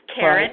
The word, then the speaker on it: Karan: It's Karen, right. – K-A-R-A-N, dot WAIGAND at SBA.gov, G-O-V, Karen Wagon.